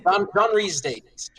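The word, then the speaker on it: John Rhys-Davies: John Rhys Davies.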